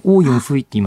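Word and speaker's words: 奥运会: あのオーユンフイって言いますよね、確か。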